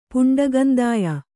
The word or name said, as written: ಪುಂಡಗಂದಾಯ